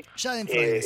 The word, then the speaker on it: Schadenfreude: Schadenfreude, sí.